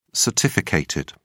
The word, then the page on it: certificated: British English